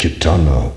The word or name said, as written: kitana